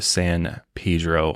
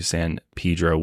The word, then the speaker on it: San Pedro: Again, there's a couple different pronunciations when it comes to San Pedro. We're going with San Pedro because we looked into it. Sounds like that's the correct way to say it. A lot of people say San Pedro.